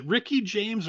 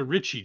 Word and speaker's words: Richie: You mentioned, is it Ricky James or Richie James? – Richie. – Okay, Richie James. Yeah. It is spelled Richie, but I thought maybe it's Ricky.